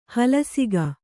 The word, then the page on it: ಹಲಸಿಗ: halasiga